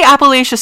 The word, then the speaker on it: Appalachia: I want to say Appalachia so bad. – Yes, Appalachian, that is what I have said my entire life, but we literally looked it up, and apparently it is pronounced Appalachia.